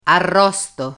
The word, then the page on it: arrosto: Italian